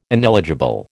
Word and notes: ineligible: American English

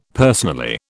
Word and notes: personally: British English

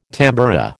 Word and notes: tamboura: American English

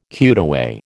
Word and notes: cutaway: American English

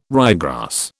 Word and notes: ryegrass: British English

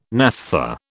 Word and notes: naphtha: British English

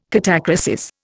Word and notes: catachresis: Indian

